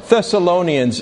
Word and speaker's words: Thessalonians: What did I say the first time? Goodness me. All right. Thessalonians is the correct answer to the confusing introduction that I just gave.